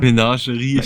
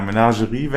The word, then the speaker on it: Menagerie: Wieder der gute Mengari, wie wird das ausgesprochen? Mengarin, Wächterin? – Menagerie, ich habe mich für uns gefragt. – Ja, Menagerie, Wächterin. 6 Mana, 5-5er.